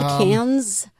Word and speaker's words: Cannes: I either said Cannes or Canis. And they're like, no, it's pronounced can. – Yeah, but it's C-A-N-N-E-S.